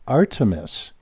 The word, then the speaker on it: Artemis: And so this is this armistice, the, not armistice, excuse me, I'm going to pronounce it correctly. Artemis.